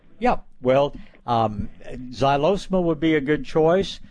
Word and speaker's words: Xylosma: Yeah, look for a vine called Xylosma. It's spelled X-Y-L-O-S-M-A.